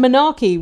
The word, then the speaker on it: monarchy: Oh, and monarchy, which I pronounced menarche because I'd never heard it. out loud.